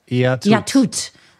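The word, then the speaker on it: Yatut (Я тут): What is Yatut. – That? How do you say that? – Yatut.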